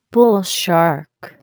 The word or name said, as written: bull shark